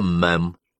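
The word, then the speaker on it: madam: Королеву мадам, произносится мэм.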